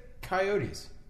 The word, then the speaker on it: coyotes: I think I say Coyotes. – Yeah, you do, because that's how it's supposed to be pronounced.